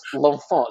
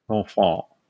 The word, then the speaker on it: L'Enfant: Okay, so it's L'Enfant. So it's L apostrophe E N F A N T. So the baby in French, in La Francais. L'Enfant poster. – L'Enfant.